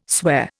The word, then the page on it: sware: Indian